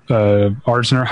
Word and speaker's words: Arzner: Well, The Bride Wore Red is the Dorothy Asner, Arzner. How do you say her last name? – Arzner. – Yeah.